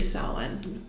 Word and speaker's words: Samhain: and especially especially Samhain, I guess is technically how it's supposed to be pronounced.